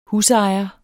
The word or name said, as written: husejer